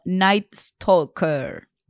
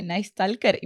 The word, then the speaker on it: Night Stalker: No, estaba escribiendo y le pregunté a Pokechu, ¿se dice Nice Talker? Y me dice, no, se dice Nice Talker.